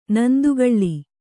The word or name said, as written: ನಂದಿಕೋಲು